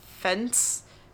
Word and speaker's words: Fentz: So this is the story of Rudolph Fet- Fentz, F E N T Z. I oh. – hope I'm saying that right. Sounds like fence.